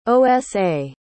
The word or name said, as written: osa